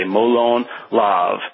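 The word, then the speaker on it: Molon Labe: And I'm going to say it right this time, James. I'm going to say Molon Lav.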